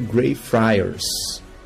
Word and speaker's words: Greyfriars: Se você estiver em Edimburgo, na Escócia, e quiser passar um pouco de medo, visite o cemitério Greyfriars.